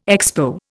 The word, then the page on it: Expo: Indian